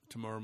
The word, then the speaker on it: Temuera: Tamora is how you pronounce that? – Yep, Tamora Morrison.